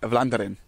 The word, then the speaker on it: Vlaanderen: And we are outside the offices of Cycling Vlaanderen.